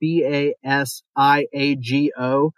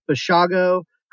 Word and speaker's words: Basiago: Now, his last name is spelled B A S I A G O. I've seen people pronounce it Basiago. I'm going with Bashago because he did an interview with Art Bell, who's pretty well known for this kind of paranormal time travel kind of stuff.